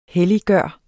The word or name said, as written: helliggøre